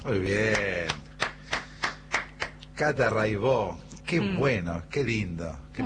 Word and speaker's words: Raibaut: Buenísimo, Cata Raibó. Se escribe Raibaut, métanse, búsquenla, Rai con Y, Baut con B larga. Catalina Raibaut, Raibó se dice.